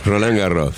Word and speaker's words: Roland Garros: Roland Garros, si no sabe pronunciar, lo lamento, yo sé pronunciar bien. Esto se pronuncia. ¿No es cierto, Mariano? – ¿Cómo va, equipo? ¿Todo bien por ahí? Así, está muy bien pronunciado.